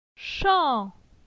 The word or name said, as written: Chan